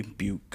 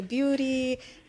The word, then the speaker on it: Beut: Ooh, and she is the mind behind Creatively Beut Goods. – Yes. Thank you for getting the name right. Sometimes people say beauty because Beut is a slang word, you know, short for beautiful.